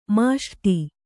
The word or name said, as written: ಮೊಸರೊಡೆ